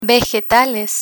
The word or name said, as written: vegetales